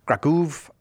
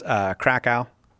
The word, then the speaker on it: Kraków: Pronounced in the United States, Krakow, but not everybody has my kind of high-quality Polish pronunciation, so you can say Krakow if you want, and you are welcome to call.